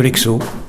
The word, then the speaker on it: Hecso: L'e-mail, ça serait l'e-mail de la ferme de l'Exo, comme ça se prononce, ferme de l'Exo.